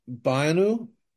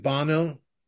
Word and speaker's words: Banu: There's a on Survivor, there's this guy, his name is Banu. It sounds like Bono, but it's Banu.